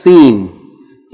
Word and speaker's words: Seen: Okay, also, before we read the lesson today, I want to just mention that the letter Seen is pronounced like the S in sun. The S in sun, S-U-N. but never like the S in rose. R-O-S-E.